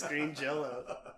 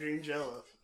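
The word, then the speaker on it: Green Jellÿ: You're still pronouncing it as Green Jello.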